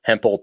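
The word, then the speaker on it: Hemphill: Hempel, or I was going to say spelled hemp Hill, but I'm pretty sure it's Hempel. – Yeah. Okay. Hempel, Texas.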